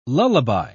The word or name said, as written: lullaby